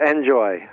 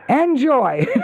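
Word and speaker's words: En-Joie: And so I tried to pronounce the one course that you worked on, Anjouan. How do you pronounce that in American? – I'm sorry, which one? – The PGA and Champions Tour host. ENJOIE. – Oh, that's called Enjoy.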